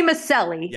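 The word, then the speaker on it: Micelli: Say it like an Italian. – Oh. Tony Maselli, yeah.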